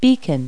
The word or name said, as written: beacon